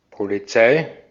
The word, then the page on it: polis: /pʊˈliːs/